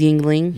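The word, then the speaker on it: Yuengling: This is Yingling. – Yingling.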